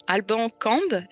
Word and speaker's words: Alban Cambe: Alors pour parler de ce sujet-là, aujourd'hui j'ai on a à nos côtés Alban, Alban Cambe. Est-ce que c'est bien comme ça qu'on prononce Alban Oui, c'est super.